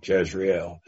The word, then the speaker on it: Jezreel: How is it properly pronounced? Jezreel? Okay, Jezreel. Well, all of my 150 years of preaching, I've always called it the Valley of Jezreel.